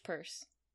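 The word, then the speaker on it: Purce: How do you pronounce her name? – Midge Purse.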